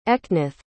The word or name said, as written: Eknath